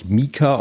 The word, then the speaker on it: Meeker: Also wenn man mal mit den Sommerhimbeeren anfängt, die Sorte Meka, also die schreibt sich M-E-E-K-E-R, wird, glaube ich, Mika ausgesprochen.